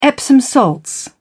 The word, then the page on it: epsom salts: British English